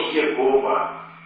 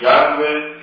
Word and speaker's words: Яхве: Получилось слово Иегова. Вот впоследствии в 19 веке ученые, обратившись к отцам церкви, поняли, что это ошибочное чтение, и в настоящее время условно имя читается как Яхве или Яхве.